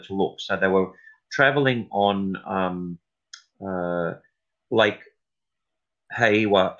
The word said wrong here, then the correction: Hawea: Albert Town Road, and uh, Hawea, I think it's pronounced. Hawea, sorry, Albert Good.